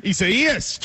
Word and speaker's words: Isaias: Isaias. – Isaias, trying to get away from it.